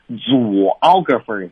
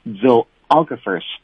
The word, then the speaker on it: zoographers: 我相信好多同学一看这些词啊会读成 zoographers, 是吧，因为你会发现 ,ZOO, 它是动物园嘛 ,zoo, 啊。当然我们说单词和那个词根呐、啊、它还是有区别的啊，不过意思是一样的，那 zoo 是动物园，这个 ,ZOO 啊还真就表示的是动物的意思啊。所以大家首先在发音上注意了，要读成的是 zoographers。